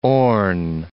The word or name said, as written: Orne